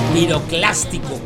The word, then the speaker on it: piroclástico: Material piroplástico. Piroclástico, coño, que lo pronuncio mal.